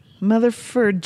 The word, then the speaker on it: MTHFR: And mostly the way to pronounce it, because there are no vowels. Motherfur gene confusion explained.